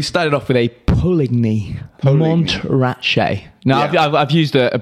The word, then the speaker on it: Montrachet: Montrachet. – Montrachet.